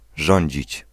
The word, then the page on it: gouverner: /ɡu.vɛʁ.ne/